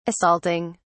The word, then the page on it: assaulting: British English